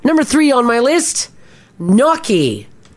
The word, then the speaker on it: gnocchi: Gnocchi. – Yes, double CH in Italian sound like the letter K. So gnocchi. Just replace those letters with a K and it'll make a lot more sense.